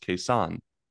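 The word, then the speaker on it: Cason: Yeah, he was in his, like, one of his first interviews after being on the Thunder, he said people have been pronouncing it wrong. It's actually pronounced Kayson. It's Kayson Wallace.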